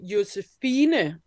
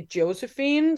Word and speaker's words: Josefine: I think in Danish, I would say Josefine. And then in English, I would probably say Josephine.